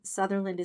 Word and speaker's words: Sutherland: And by the way, my name Sutherland is spelled like Kiefer Sutherland. There's no O. It's just S-U-T-H.